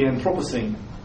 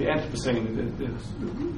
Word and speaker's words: Anthropocene: Now, in terms of where we are now, we're living in an epoch that's been proposed to be called the Anthropocene, or the Anthropocene, both pronunciations are acceptable,